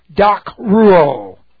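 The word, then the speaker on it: Docruo (δακρύω): In describing the weeping of Jesus Christ, we have the aorist active indicative of the verb docruo. For those of you interested, it is doc, transliterating docruo. Long O. Docruo.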